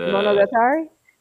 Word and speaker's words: Monogatari: All it is the Monogatari? – Yeah.